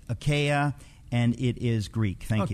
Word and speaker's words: Achaia: How do you pronounce A-C-H-A-I-A from 1 Corinthians? Is it Greek or Latin? What name is that? – Achaia. – Achaia. – Achaia from 1 Corinthians is Greek.